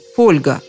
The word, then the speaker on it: фольга: Из латинского получилось немецкое «фолии», из немецкого – польское «фолия», и уже потом «фольга» с ударением на «о».